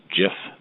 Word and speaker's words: GIF: And what he said was that it's pronounced GIF, not GIF.